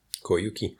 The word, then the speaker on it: Koyuki: Koyuki.